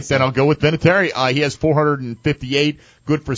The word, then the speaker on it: Vinatieri: And Vinatieri, right? – Vinatieri, however, how do you say it? – Vinatieri. – Vinatieri? – That's it. That's how I say it.